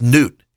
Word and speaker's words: Nut: and that's a judgment on the goddess Nut, N-E-W-T is how you'd pronounce it,